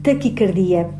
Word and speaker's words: taquicardia: Trata-se de uma palavra grave, ou seja, a sílaba tónica é a penúltima, di, pelo que não deve ser escrita com qualquer acento gráfico e a sua pronúncia é taquicardia, com A fechado.